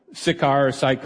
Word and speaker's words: Sychar: And you see Galilee up north, and right in the middle in the gray area there is Samaria, and there's Sychar can be pronounced really either way, right in the middle of Samaria.